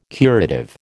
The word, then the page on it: curative: American English